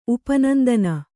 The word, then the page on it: ಉಪನಂದನ: kannada